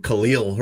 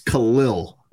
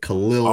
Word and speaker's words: Khalil: He's running for, 50-yard touchdowns, and they're calling him Khalil Herbert, and it's not his name. It's Khalil. – Khalil. – Lil. – Not Khalil. – Like it ends with like L-I-L-L would be how you pronounce it. Khalil.